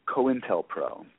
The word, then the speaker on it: COINTELPRO: just Google COINTELPRO. That's spelled C-O-I-N, like coin.